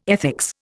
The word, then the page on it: ethics: Indian